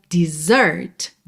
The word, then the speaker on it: desert: Se escreve D-E-S-E-R-T. Alright? D-E-S-E-R-T. A sílaba tônica é a segunda, então dessert, dessert. E esse S tem som de Z, right? Dessert.